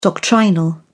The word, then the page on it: doctrinal: British English